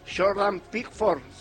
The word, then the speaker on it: Jordan Pickford: Jordan Pickford será el arquero.